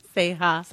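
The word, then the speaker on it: Ceja: I'm going to butcher. Seha? Seha? – Seha.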